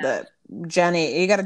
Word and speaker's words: Jeannie: Jean, yeah, I want to say Gianni, but it's going to be Jeannie. Right? Oh, Jenny Mai.